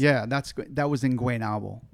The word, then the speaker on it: Guaynabo: Guaynabo.